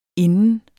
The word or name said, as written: inden